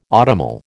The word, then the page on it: autumnal: American English